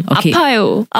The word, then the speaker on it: 아파요: So the emphasis is on the 아, the 파, or the 요? – 파. Yes, it's 아파요. On the 파. 아파요.